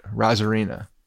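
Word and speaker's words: Arozarena: I want you to get rid of the A in front of his name and just say it, Rosarena, because that's how you say it. Randy Rosarena. Forget about the A in front. Don't even pronounce it.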